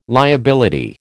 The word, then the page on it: liability: American English